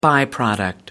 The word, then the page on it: by product: American English